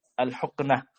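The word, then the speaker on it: al-huknah (الحقنة): adalah al-hulqah, al-huknah. Nah, al-huknah, afwan. Karena keliru dalam baca, al-huknah. Ini sebagai pembatal yang kedua, al-huknah fi ahadi as-sabilain. Ini pembatal yang kedua, bukan al-hulqah membacanya, al-huknah. Anda salah lihat dari nuskhah yang asliyah tadi.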